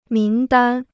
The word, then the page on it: 名单: míng dān